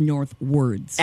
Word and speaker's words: Northwords: And I suppose it's spelled with the O, so it's Northwords.